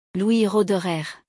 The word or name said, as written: Louis Roederer